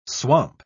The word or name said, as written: swamp